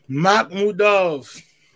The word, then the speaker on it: Makhmudov: Makhmudov. Is that what am I saying it right? – Makhmudov.